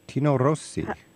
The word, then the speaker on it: Rossi: Tino Rossi. – How do you spell that? – R-O-S-S-I.